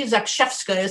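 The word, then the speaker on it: Zakrzewska: And here's Marie Zakshevska, is how it's pronounced, who turned up on Elizabeth's doorstep.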